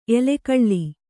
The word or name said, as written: ಎಲುಗುಳಿ